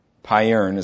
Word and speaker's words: Payerne: In June of 1532, William Farrell went to the town of Payerne. It's spelled P-Y-E-R-N-E. Payerne is how I'm saying it.